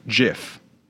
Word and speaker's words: GIF: The creator, Steve Wilhite, pronounced it GIF with a soft G.